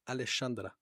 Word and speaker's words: Alexandra: Das X spricht man mit Sch aus. Hallo, Alexandra.